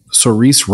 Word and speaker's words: Saoirse: And then there's Foe, F-O-E. It has Paul Mescal. It has, do you pronounce her name Saoirse Ronan? – I think so.